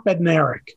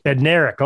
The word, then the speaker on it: Bednarik: okay, so I'm so I'm messing his name up. How, how do you say that again? Chuck Bednarik. – Bednarik. Okay, I was, I was making one of the uh, consonants silent.